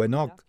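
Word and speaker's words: Hanok (חנוך): Se remonta al libro de Génesis, donde hemos hablado de Hanok o Enoch, según como pronuncies su nombre. En hebreo es Hanok.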